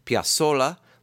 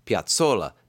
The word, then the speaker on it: Piazzolla: His parents were Argentine descendants of Italian immigrants, which is why you sometimes hear his last name pronounced Piazzolla, the Spanish way, and sometimes you also hear it pronounced Piazzolla, the Italian way.